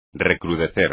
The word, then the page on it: recrudecer: espagnol